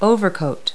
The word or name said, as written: overcoat